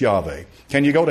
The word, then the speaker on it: Yahweh: Now today there's a big dog fight about the fact that they say, well, Yod-Heh-Vav-Heh with the Masorete vowel points, they just took it from Adonai and they put those vowel points on it, and it should be pronounced Yahweh.